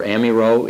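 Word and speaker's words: Amyraut: Remember, Amiraux is the French Reformed theologian of Saumur who develops the four-point Calvinism.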